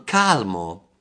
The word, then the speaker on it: calm: Ok, allora, calmo. In inglese è scritto calmo, è scritto C-A-L-M, ma l'L non si pronuncia calm, ok? – Ok. – Calmo, in inglese calm. Senza pronunciare l'L.